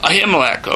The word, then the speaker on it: Ahimelech: Ahimelech, okay, I'm mispronouncing it.